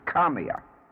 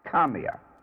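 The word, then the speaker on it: Kamea: and is one which is so often mispronounced, is Kamiya. Kamiya.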